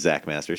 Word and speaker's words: Xakkmaster: You can find me at Critiker, where my username is Zach Master, spelled with an X, XAKKMASTER. And I post my short movie reviews.